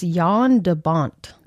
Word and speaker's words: Jan de Bont: Okay, so director is, and I had to write it down how to pronounce his name because I knew I was going to get it wrong. It's Jan de Bont. – Oh, good job. – But it's spelled Jan.